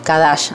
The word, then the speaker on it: Qadash: Q-A-D-A-S-H, Kadash, así se escribe, es una palabra hebrea,